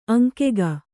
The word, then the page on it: ಅಂಗಿಗೆ: aŋgige